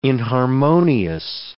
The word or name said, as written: inharmonious